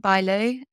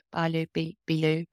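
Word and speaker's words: Bilyeu: Impact Theory, and it's Tom Bailu, and I think they pronounce it Bailu, Bailu.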